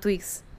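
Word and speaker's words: twigs: Es FKA Twix. Twix es como. Ah, no, Twix, de hecho se, llama, se dice Twix, se pronuncia así en inglés.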